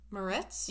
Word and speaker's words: Moretz: Morozitz? – Moretz? – Yeah, that one.